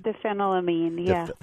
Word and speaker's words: diphenylamine: Let's talk about this particular substance that was banned in Europe and was the whole, see if I'm saying this right, diphenylamine, is that right? – Diphenylamine, yeah. – Thank you. But you said it much better than I.